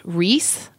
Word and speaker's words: Riess: This is made by an Austrian company called Ries. I think is how it'd be pronounced, R-I-E-S-S.